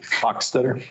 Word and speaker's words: Hochstetter: Hochstetter.